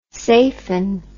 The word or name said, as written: safen